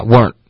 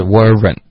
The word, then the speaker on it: weren't: 可是我不建议大家练的时候发 weren't 的这个发音，还是用两个音节这样子比较清楚。你的发音是地道的发音，可是还是要发的比较清楚一点的。就是 weren't。对，we weren't。